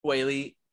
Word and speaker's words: Weili: Weili. – Yeah, Weili, I feel like she needs to keep her like at a distance almost and kind of look for the counter strikes.